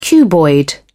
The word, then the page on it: cuboid: British English